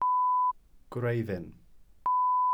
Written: gwreiddyn

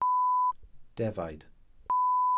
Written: defaid

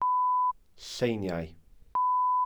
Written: lleiniau

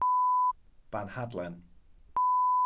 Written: banhadlen

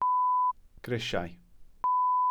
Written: grisiau

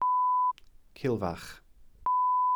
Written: cilfach

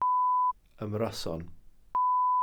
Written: ymryson

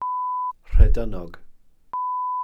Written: rhedyn(n)og

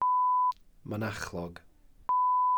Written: mynachlog

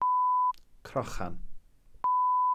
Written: crochan

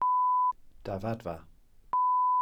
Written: dafadfa

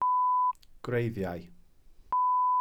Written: gwreiddiau

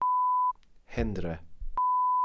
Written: hendre(f)

